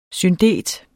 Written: SYNDET

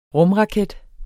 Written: rumraket

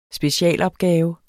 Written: specialopgave

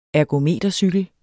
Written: ergometercykel

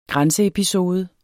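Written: GRÆNSEEPISODE

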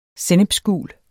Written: sennepsgul

